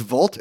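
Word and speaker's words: Wolt: They bought a company that's, I think it's pronounced Vault. It's spelled with a W though.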